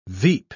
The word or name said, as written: veep